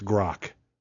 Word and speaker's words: Grok: So Grok, am I even saying that right? Grok? Okay, it's Grok.